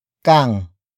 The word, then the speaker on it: kang: Another example, huwag na huwag kang aalis. Huwag na huwag kang aalis. Huwag na huwag kang aalis. It means don't you ever leave. Word for word it's huwag na huwag kang aalis.